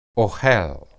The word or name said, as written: ohel